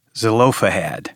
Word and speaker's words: Zelophehad: Zelophehad.